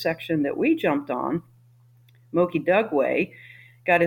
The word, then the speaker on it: Moki Dugway: It's called Moki Dugway, and that's spelled M-O-K-I, Moki Dugway, D-U-G-W-A-Y.